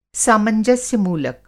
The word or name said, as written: सामंजस्य-मूलक